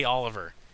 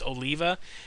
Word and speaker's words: Oliva: The last three times I've talked to him, I call him Jay Oliver, because it's spelt close enough to Oliver, but it's Oliva. And every time I'm like, I'm here with Jay Oliver, and he's like, it's Oliva.